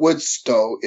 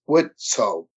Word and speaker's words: Widtsoe: It's not Woodstow, it's Widtsoe. – Widtsoe.